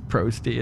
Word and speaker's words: Prosty: And Prosty, as everybody knows, rhymes with toasty.